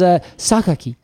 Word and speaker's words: Sakaki: I looked it up on the internet, as I am wont to do, and it is Sakaki. – Yes. – And I love that. I had to remember that Japanese pronunciations are vastly different than pronunciations of Latin-based languages.